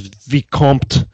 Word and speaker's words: Vicomte: He was a Viscount, or Viscount, depending on how you want to pronounce it. Of course, it's French, so it was Vicomte.